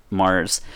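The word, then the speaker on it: März: However, it's actually because in German, March is pronounced Mars, and it's the beer itself is forbidden to be brewed between April 24th and September 28th.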